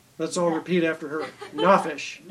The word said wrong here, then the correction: nefesh: Na, the emphasis on the M-E. – Ne. – Nefesh. – Nefesh. All right, nefesh.